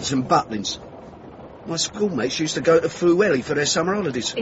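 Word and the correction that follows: Pwllheli: It's pronounced Pushheli.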